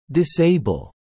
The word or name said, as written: disable